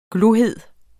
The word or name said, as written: glohed